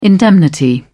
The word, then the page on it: indemnity: British English